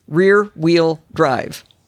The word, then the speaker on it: rear wheel drive: By the way I say it, because I actually have trouble saying it too, is think of Wheel of Fortune. Rear wheel drive. Wheel of Fortune. If you say it like that, it comes out just fine.